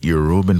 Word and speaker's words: Yoruba: A favorite Yoruban folktale? – Yes. – So I don't have a favorite Yoruba folktale. – Is that how to say it? – Yeah, Yoruba.